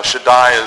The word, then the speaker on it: Shaddai: Shaddai is the one that most of us know as S-H-A-D-D-A-I, and that means God Almighty.